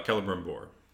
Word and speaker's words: Celebrimbor: Oh, Celebrimbor.